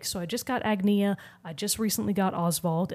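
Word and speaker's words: Agnea: I can now confirm her name is pronounced Agnia. – Okay. – Yeah, it's not Anya or any of the other ways that Bravely Default chose to pronounce the name Agnes Agnes, which is what threw me off because I thought they would be really similar, but they're not.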